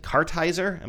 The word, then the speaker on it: Kartheiser: Vincent Kartheiser, am I saying his name right? – I think that's right, yeah.